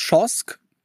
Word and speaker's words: kiosk: Kiosk heißt auf Schweden übrigens Schosk. – Mhm. – Und wird Kiosk geschrieben.